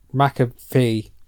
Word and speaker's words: McAfee: and Pat McAfee? – Ah, you got him.